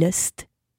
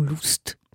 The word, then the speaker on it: Lust: La BD en question, c'est celle d'Ouli Lust ou Lust, ça dépend si vous voulez le prononcer à l'allemande ou à l'anglaise. L-U-S-T, trop n'est pas assez.